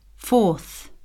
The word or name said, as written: fourth